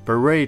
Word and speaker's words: beret: beret 它也原本是从法文，所以最后的那个 t 它不发音。